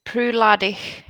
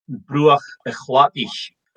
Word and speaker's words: Bruichladdich: So the Gaelic is Bruichladdich, but in Ila they don't pronounce a final ich. They just have e. So that's why it's not Bruichladdich. It's just Bruichladdich.